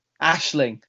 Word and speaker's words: Aisling: How do you pronounce Aisling's name? Is it Aisling? – Ashling.